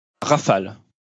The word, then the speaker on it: Rafale: Rafale. – Well, I can't quite put the French pronunciation on like you do, but it's Rafale or something along those lines.